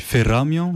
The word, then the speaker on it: Feramia: Et tu représentes Feramia, c'est ça? C'est comme ça qu'on prononce? – Oui, on dit Feramia, mais Feramia, ça sera...